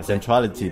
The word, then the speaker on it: Centrality: 对，Centrality，对 Centrality 的这个联合创始人，呃，那么 自己自己介绍一下自己啊。